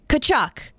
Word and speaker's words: Tkachuk: You don't pronounce the T. – Okay, the T does not exist. – Okay, Tkachuk.